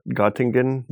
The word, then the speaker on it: Göttingen: And he entered also to, after several attempts, he entered to the Göttingen University. I'm saying it wrong, probably. – Which it's, one? Where? – So the same name as the observatory, and Göttingen. – Yeah, thank you.